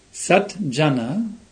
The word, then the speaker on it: Satjana: Sat Jana, when in Sandhi it becomes Satjana for pronunciation purposes. You merge it, so Sat becomes a J.